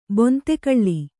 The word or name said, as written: ಬಾಂದುಕಲ್ಲು